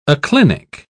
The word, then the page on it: aclinic: British English